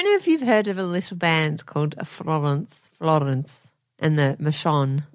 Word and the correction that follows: Florence and the Machine: Florence and the Machine.